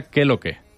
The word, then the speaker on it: KLK: Que es, se escribe KLK y se pronuncia Keloke.